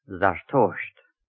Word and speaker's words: Zartosht: The name Zoroaster in present-day Persian is called Zartosht, and in the ancient Avesta language is pronounced Zarathustra.